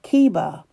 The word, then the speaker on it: Chebar: Now the CH is pronounced like a K, so he's by the river Chebar, no doubt anxious and disconsolate.